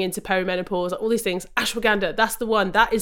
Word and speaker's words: ashwagandha: So, for example, this is one, maybe going off topic again ashwanga. I don't know if I'm saying it right, but this is ashwagandha. Ashwagandha, yeah. thank you. Ashwagandha.